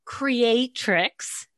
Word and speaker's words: creatrix: So you're talking about living as art, living as a creatrix. Is that, am I saying it right? – You are saying it right, yes.